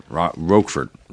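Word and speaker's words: Rocafort: How do you say that? Roquefort. – Yes. – Like the dressing.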